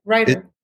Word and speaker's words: Reiter: Reiter.